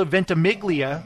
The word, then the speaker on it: Ventimiglia: This Is Us star Milo Ventimiglia. Am I saying that right? – I think so.